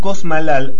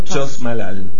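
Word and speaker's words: Chos Malal: Después en la ciudad de Cosmalal o Chosmalal, de acuerdo a como te guste mencionarlo, porque lo he escuchado de las dos maneras. – Yo lo pronuncio como está escrito y digo Chosmalal.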